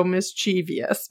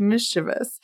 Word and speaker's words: mischievous: Everyone just look at how that word is spelled and then think about how you'd say it, because my brain wants to go mischievous, but like it is not mischievous. It's mischievous.